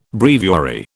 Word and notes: breviary: British English

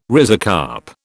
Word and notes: rhizocarp: British English

